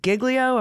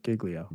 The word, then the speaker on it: Giglio: And this is a motion to compel, is it Giglio? Am I saying that right? – Yeah, you got it. Giglio.